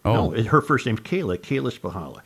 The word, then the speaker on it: Spychalla: Her last name is spelled S-P-Y-C-H-A-L-L-A. And the reason I know it's pronounced Spahala is because that was the name of a family I grew up with in Matamida.